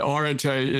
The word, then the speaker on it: Areté: He has granted me the blessing that Erdete is, in fact, in a proper way to pronounce it.